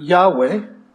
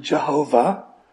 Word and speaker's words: Yahweh: For example, in Exodus 3, he identified himself to Moses as, for the very first time, as in Hebrew, Yahweh. Some people choose to pronounce it Jehovah, Yahweh.